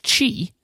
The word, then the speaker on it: Qi: It is pronounced Qi, but spelled QI, which.